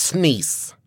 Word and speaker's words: SNES: The other day, my boss I believe it's pronounced Sneeze.